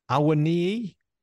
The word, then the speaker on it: Awoniyi: Richards, Awoniyi.